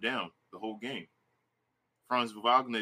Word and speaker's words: Wagner: And also, Franz Wagner, I think that's how you pronounce Wagner.